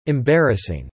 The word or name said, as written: embarrassing